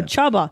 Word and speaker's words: Csaba: Oh, Chubba. Chubba. That's how do you spell that? – C-S-A-B-A.